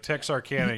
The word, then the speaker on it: Texarkana: Is it pronounced Texarkana? – Yeah, Texarkana.